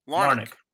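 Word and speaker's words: Larnach: Larnick. – Okay, I gave him my – look, I was in the ballpark. Monday, Larnick had quite a day, going 2-for-3 with three runs batted in.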